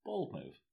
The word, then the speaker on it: Bold Move: Bold Move, not Bold Move. – Am I saying it wrong? – Yeah. Emphasis on bold rather than move. – You say it. – Bold Move.